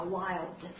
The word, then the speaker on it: wild: 注意这个 Dark L 的发音啊，wild 啊，野生的，wild animal, wildlife。